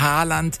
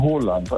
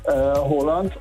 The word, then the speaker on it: Haaland: Erling Haaland ist falsch, ne? – Ja, das ist absolut falsch. Er wird ausgesprochen Haaland, also mit O, ja. – Haaland. Und zwei A's werden als O ausgesprochen und ein A mit einem Kringel oben drauf ist eigentlich auch eine Aussprache, ein O.